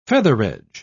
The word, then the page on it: featheredge: British English